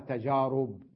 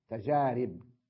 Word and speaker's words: تجارب: بعضهم يلفظها تجارب بالضم، خطأ، تجارب، ونقول تجربة لا نقول تجربة، والناس كلها تنطقها، غالبية الناس تجربة، تجارب، خطأ، تجارب وتجربة هكذا.